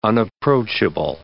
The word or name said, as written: unapproachable